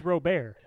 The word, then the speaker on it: Robert: Probably, I don't know if it's Robert, but I'm going to pronounce it Robert. – It's Robert.